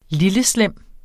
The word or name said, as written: lilleslem